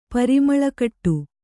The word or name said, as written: ಪರಿಮಳಕಟ್ಟು